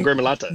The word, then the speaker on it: gremolata: But could you take us through maybe the fresh pappardelle and lemon gremolata recipe? Perfect. Have I said that correctly? – Yeah, lemon gremolata.